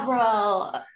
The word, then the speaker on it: Abril: Abril, got it.